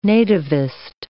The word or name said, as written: nativist